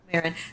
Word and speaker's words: Maron: and I think Seth Rogen and this Mark, Maron? Is that how you pronounce his name? I don't know. – Mark Maron. – Maron.